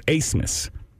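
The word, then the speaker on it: Abmas: Can you use it in a sentence where you tell me why you pronounce a B with an S? Mm-hmm. Asmus. His name is Asmus.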